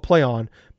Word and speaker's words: Plaion: But they changed their name to spelled Plaion. And he said it sounds just as it's spelled. So then everybody's like, was it Plaion? So it's actually called Plaion.